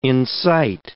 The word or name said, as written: incite